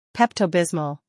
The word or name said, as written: Pepto-Bismol